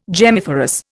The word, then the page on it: gemmiferous: Indian